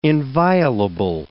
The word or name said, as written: inviolable